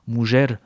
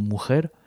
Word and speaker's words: mujer: mujer, avec la prononciation ancienne, la femme, parce qu'en espagnol contemporain on prononcerait plutôt mujer, hijo, hija, fils, fille.